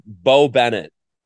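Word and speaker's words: Beau Bennett: All right, next one up, Bo Bennett. And in this instance, Bo is spelled B-E-A-U.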